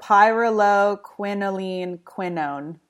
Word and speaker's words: pyrroloquinoline quinone: Pyrroloquinoline quinone.